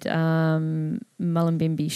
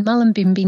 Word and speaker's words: Mullumbimby: And so you're located Mullumbimby Shire. Is that how you Yes. – pronounce it? – Yes. Currently I'm in Mullumbimby, New South Wales, and I do move back and forth between New Zealand as well.